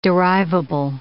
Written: derivable

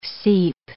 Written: cepe

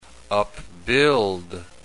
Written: upbuild

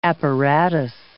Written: apparatus

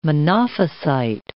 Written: Monophysite

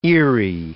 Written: eerie

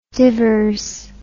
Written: divvers

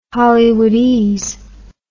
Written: hollywoodese

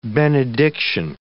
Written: benediction

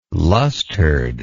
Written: lustered

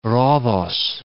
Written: Rodhos

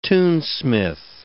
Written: tunesmith

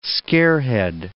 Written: scarehead

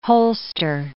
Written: holster

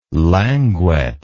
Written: languette

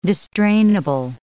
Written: distrainable

